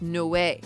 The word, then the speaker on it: Noé: It's pronounced no way. – That's true. It is pronounced no way.